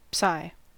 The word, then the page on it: psi: English